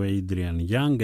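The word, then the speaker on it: Adrian Younge: Βγάλαμε το σκύλο βόλτα, τον βάλαμε να φυλάξει και τις μάντρες και πάμε στο καινούριο, σε ένα από τα καινούργια κομμάτια του Adrian Young, έτσι προφέρεται παρόλο που γράφεται με E στο τέλος.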